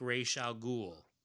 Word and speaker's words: Ra's al Ghul: He says, we know his name translates as head of the demon, which in Arabic and related languages like Urdu would sound like Ra's al Ghul. Yes, that kind of ghoul. Even if some movie characters pronounce it Ra's al Ghul.